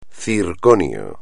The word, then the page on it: circonio: espagnol